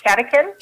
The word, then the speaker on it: catechin: The catechin? – Yes. So it says green tea contains three times the catechins of black tea. – So what's a catechin? – Right. – So reinforce, what is catechin? – Right.